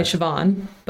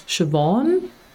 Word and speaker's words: Siobhan: But I have my dad's cousin's name is Siobhan and I would say Siobhan, but I've been told it's Siobhan.